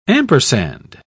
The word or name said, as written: ampersand